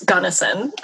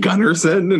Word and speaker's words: Gunnison: I have been saying the name of that beach wrong for the entire time. – I've been saying it wrong, too. It's Gunnison. – I keep saying Gunnarsson, and I'm like, me too, like, hey.